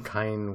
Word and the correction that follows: Kanye: West. All right, all right. Uh, you got caught that you were like part of pop culture, so now you have to butcher it. No, what's his name? Kanye.